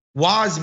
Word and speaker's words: Waz: And so Waz became a nice, like, I just found that it was the easiest way for people to say my name, right? And so it becomes my sort of American, or as I like to say, Yankee pronunciation.